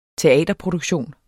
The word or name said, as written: TEATERPRODUKTION